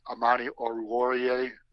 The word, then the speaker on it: Amani Oruwariye: You look at Okuda at one cornerback, Amani Oruwariye. I think I said that right again.